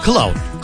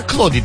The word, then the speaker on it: Claude: yapay zeka var bir tane biliyorsunuz ya, bir sürü var da onlardan bir tanesi Claude. Cloud. Cloud değil. Cloud böyle yazılmıyor. Birazcık İngilizcemiz var. Claude diye okunuyordur eminim ki.